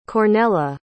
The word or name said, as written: Cornella